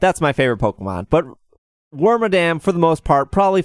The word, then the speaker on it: Wormadam: I would argue that that Wormadam, or however you say its name. I think that's how you say it. – Wormadam, yeah.